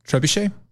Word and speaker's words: trebuchet: It's a trebuchet.